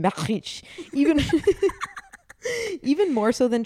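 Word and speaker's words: Matthijs: It's spelled Matt H-I-J-S. How would you pronounce that? – Matt H-I-J. Yeah. – Matches. – Matches. – Matches.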